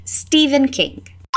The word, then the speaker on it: Stephen: A lot of people struggle with the pronunciation of this author's name because it's spelled S T E P H E N, but it's the same as Stephen with a V. Repeat Stephen King.